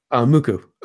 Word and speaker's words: MUCC: Muku. Spelled M-U-C-C. It's pronounced Muku.